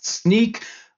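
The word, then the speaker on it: Snyk: Sneak, again, pronounced like a pair of sneakers or sneaking around, it's a developer security platform.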